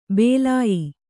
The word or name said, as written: ಬಾಲೇಯ